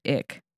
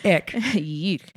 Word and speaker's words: Icke: And in modern day, the whole lizard people theory has been popularized by a guy named David Icke. – Great name. That is strong name. – Yeah. That's how I was like, is this how you pronounce it? That's how Google told me to pronounce it.